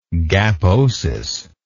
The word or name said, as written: gaposis